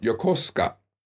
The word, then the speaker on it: Yokosuka: And there's a city not too far from me, Yokosuka. But it's pronounced Yokosuka.